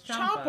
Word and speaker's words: Ciampa: Yeah, it's Italian. The C-I makes a C-H sound, so it's Ciampa.